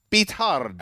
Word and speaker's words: Beathard: Beathard.